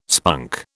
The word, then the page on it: spunk: British English